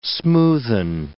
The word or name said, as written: smoothen